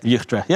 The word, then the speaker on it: Utrecht: Utrecht. Yeah, you say it correctly.